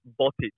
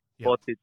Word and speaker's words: Botic: That always, a lot of people do get confused because they always say Botic or Botic. – Yep. – But there's a bit of an accent with the itch at the end.